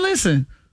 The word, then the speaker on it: listen: Listen is another one where the English words has a silent letter. – Silent and listen? – T. Listen. You don't hit no T in listen.